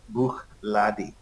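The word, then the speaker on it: Bruichladdich: Yes, so it's got the silent end. It's the CH at the end, very quiet. Brugladdie. So that's how that's pronounced.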